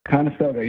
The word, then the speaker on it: Conestoga: So you attend Conestoga High School. Did I pronounce that right? – Conestoga, yeah. – Both. Conestoga. Like, uh, like Conestoga, like Conestoga wagons.